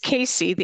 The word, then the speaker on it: CACEA: So it's CACEA. The A is silent, and we're the Canadian Association of Consulting Energy Advisors. – There you go. – CACEA.